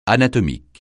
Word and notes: anatomique: francais

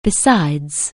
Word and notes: besides: anglais

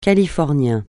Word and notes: californien: francais

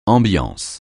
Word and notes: ambiance: francais

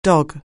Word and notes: dog: anglais

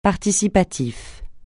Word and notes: participatif: francais

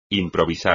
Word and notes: improvisar: espagnol

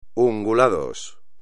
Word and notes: ungulados: espagnol

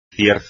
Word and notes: cierzo: espagnol

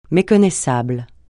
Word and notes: méconnaissable: francais

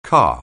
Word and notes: car: anglais

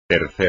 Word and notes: tercer: espagnol

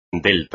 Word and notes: delta: espagnol

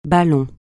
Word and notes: ballon: francais

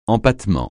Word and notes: empattement: francais

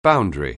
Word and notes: boundary: anglais

